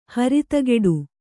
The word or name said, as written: ಹರಿತಗೆಡು